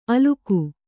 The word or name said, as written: ಅಲುಕು